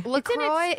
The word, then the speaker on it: LaCroix: LaCroix is the actual pronunciation. I've looked it up. – Don't you think it's LaCroix? – I thought that, but I looked it up.